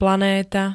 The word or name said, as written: planéta